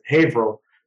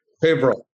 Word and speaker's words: Haverhill: As we wrap up here, if I had 24 hours in Haverhill, what should I do? – Yeah. So I have to correct you because if not, my constituents would kill me. Haverhill. – Haverhill.